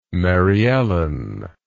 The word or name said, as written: mary ellen